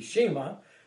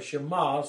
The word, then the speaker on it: Shema: Deuteronomy 6:6-9, the Shema, as it's sometimes referred to, or Shema, some would pronounce it,